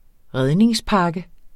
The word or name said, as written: redningspakke